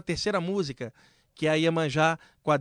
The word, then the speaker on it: Iemanjá: E como a Deva Premal é europeia, ela não consegue falar Iemanjá, ela fala Iemayá, né? A pronúncia é diferente, Iemayá, mas é Iemanjá.